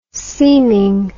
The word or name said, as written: seaming